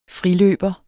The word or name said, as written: friløber